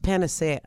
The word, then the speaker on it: panacea: It's actually pronounced panacea.